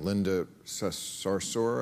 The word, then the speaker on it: Linda Sarsour: I know you were having a bit of a Twitter spat sometimes with this woman, Linda Sarsour.